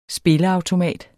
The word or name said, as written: spilleautomat